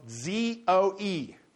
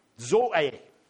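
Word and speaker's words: Zoe (ζωή): That word life actually is one that is spelled Z O E, Zoe, Zoe. That's the Greek word.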